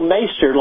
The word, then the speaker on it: Meister: It's Tool Meister, like on the Game of Thrones, Meister. – Oh, the Meister. Okay, I apologize. Okay. – It rhymes with Taster. Meister and Taster.